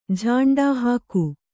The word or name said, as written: ಝಾಂಡಾಹಾಕು